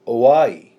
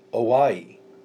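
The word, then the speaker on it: Oai (οὐαί): This word woe is actually a word that is O-U-A-I. Oai. Oai. Okay, oai in the Greek.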